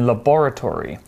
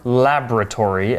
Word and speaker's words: laboratory: But he said that he died while he was working in a laboratory, or laboratory, as we might pronounce.